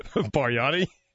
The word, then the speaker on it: Bargnani: How do you say his name again, please? – Bargnani? – Well, what's his nationality? – I have no idea. Russian? I got it? – He nailed it.